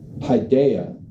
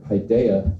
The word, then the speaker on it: paideia: The Greek term there, paideia, I believe is how you say it, paideia, it means upbringing.